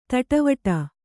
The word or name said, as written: ತಟವಟ